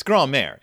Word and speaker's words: grand-mère: It's grand-mère. It's just grand-mère.